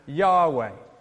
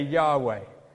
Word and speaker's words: Yahweh: Now, this is typically pronounced Yahweh, or sometimes Yahweh, but I'm going to say Yahweh.